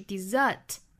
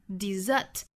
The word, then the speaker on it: desert: 那 desert 作为动词这个词的发音就发生了变化了，作为动词表示丢弃抛弃，它的发音是 desert， dessert，这时候它的发音和甜点那个单词是一样的，甜点那个单词我们注意是两个 s 啊，不是一个，是两个 s， dessert， dessert。